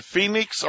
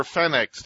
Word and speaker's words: Fenix: On eBay, you can buy Nitecore, N-I-T-E-C-O-R-E, Nitecore batteries, and Phoenix or Fenix, depending on how you pronounce it, F-E-N-I-X.